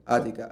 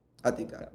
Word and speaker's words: Athica: Ática. A-T-H-I-C-A. Ática.